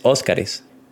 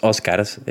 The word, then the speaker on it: Óscares: Y pues la RAE dice que el término correcto del plural de Oscar, pues es Óscares, ya que como es una palabra que termina en consonante, el plural sí tiene que ser es. Entonces no, es Óscars, es Óscares, eh, con acento en la O.